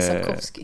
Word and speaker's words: Sapkowski: Andrei eh, Sapkowski.